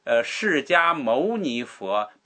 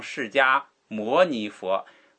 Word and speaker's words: 释迦牟尼: 哎，所以我说那个释迦牟尼佛不要念成释迦摩尼佛，莫尼摩尼都是宝珠的意思，牟尼是圣人的意思，释迦牟尼是姓释迦的族，释迦族的圣人。